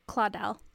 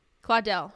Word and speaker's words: Claudel: Now we have one for Camille Claudel. I said it right, right? – Claudel. – Claudel. Huh, sorry. Yeah, Camille Claudel. Fine.